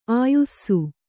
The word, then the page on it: ಆಯುಸ್ಸು: Kannada